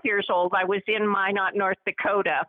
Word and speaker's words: Minot: So going back to your earlier solo, let's see if I pronounce this right. It's called Minot, North Dakota. That's where you were born? – That's correct. Yep.